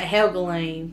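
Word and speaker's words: Helgelien: Andrew Helgelian? – I've been pronouncing it Helgelian, because, again, in my head, that's where it makes sense. L-I-E-N is like a lean. So, Helgelian.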